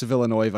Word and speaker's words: Villeneuve: It's directed by Denis Villeneuve.